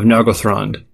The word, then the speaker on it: Nargothrond: At this point in his misadventures, Túrin has been staying in the underground elf kingdom of Nargothrond.